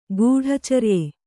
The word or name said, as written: ಗೂಢಚರ್ಯೆ